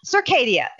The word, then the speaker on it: Circadia: Am I saying that right? – Circadia. – Yeah. Okay. I always say Circadia.